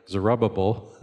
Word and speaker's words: Zerubbabel: There's Zerubbabel. It sounds like you're mispronouncing it even when you say it correctly. Zerubbabel.